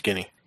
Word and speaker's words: Guinea: Is that how you say it? Guinea? Or is it Guinea? – I don't know. It's Guinea.